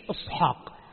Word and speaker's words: إصحاق: طيب إش إصحاق، إصحاق العبرانية حتى في اللغة العبرانية الآن إصحاق هكذا.